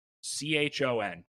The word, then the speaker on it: Chon: C H O N.